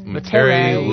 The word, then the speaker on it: Metairie: Metairie, Louisiana, and.